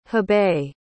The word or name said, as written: hebei